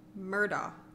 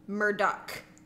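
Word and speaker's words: Murdaugh: It's a big controversy in the true crime thing because a lot of people want to say it like it's spelled, which would be Alex Murdaw. – Right. – But apparently in South Carolina... That is not how you say it, and people are very passionate about it. So it is Alec Murdoch.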